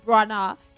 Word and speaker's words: Branagh: We slaughtered his surname. It's Branagh.